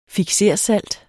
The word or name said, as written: fiksersalt